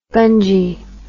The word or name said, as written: gungy